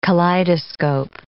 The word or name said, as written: kaleidoscope